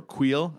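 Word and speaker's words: Kuiil: And we got them from Quill, and we got them, or Queel, I should say. It's not Quill, it's Queel. Queel.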